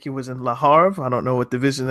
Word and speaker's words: Le Havre: This is the H is silent, so it's Le Havre.